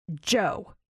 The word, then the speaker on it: Djo: I will tell you the artist of that song is Joe. D-J-O. That's how you spell it. D-J-O. The D is silent. Joe.